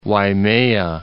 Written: Waimea Canyon

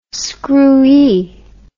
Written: screwee

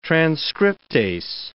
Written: transcriptase

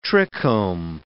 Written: trichome

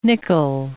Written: nickle

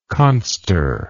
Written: conster

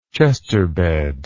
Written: chesterbed

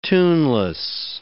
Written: tuneless

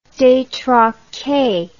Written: detraque

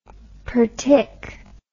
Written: partic

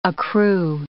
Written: accrue

